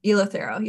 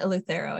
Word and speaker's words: Eleuthero: Eleuthero? – Eleuthero, yeah. – Eleuthero. Where are the rest of the ingredients? Peppermint. – Or some people pronounce it Eleuthero, I think too.